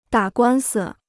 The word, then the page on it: 打官司: dǎ guān si